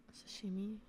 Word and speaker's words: sashimi: Sashimi. Did I say that right? Sashimi? Sashimi? – Sashimi.